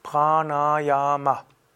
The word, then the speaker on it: Pranayama: Wenn du es also sanskritmäßig aussprechen willst, wäre es Pranayama.